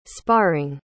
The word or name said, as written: sparring